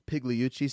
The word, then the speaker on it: Pigliucci: And what I loved so much from what Pigliucci said, the G is silent.